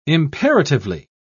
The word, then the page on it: imperatively: British English